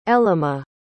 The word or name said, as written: ellema